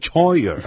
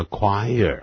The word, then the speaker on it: choir: choir 这个字啊，可要注意哦。如果不查字典，很容易念成 choir 啊。但是它发音完全不一样，叫 choir 啊。